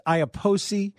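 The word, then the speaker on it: Iapoce: It's pronounced Iaposi.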